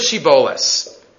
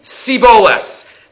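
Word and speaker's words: shibboleth: Say the word shiboles. Shiboles, some type of stalk. Vayomer, and they used to say, siboles.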